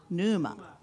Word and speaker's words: pneuma: It's a silent P. – Ah, pneuma.